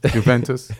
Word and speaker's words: Juventus: Juventus. – It doesn't. You don't pronounce the G or the J.